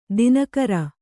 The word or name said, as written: ದಿನಕರ